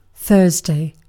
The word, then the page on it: torstai: [ˈt̪o̞rstɑi]; /ˈtors.tɑi/